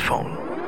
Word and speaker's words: Phaune: It's spelled P-H-A-U-N-E, but it's pronounced phone.